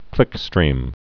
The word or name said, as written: clickstream